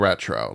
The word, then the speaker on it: retraux: It's the actual term I actually found out was an actual word, RETRAUX, for those wondering how it's spelled. – Retro.